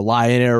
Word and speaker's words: Lion Air: Well, okay, you said, you know, Lion Air rhymes with Ryanair.